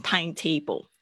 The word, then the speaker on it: timetable: 哦，这边是很明显是两个字组合在一起，但是我不要听到大家念成 timetable，跟 table 没有关系，主要是 time，所以这个发音的时候，特别是它重音要特别注意是 timetable，timetable 不是 timetable，OK，timetable。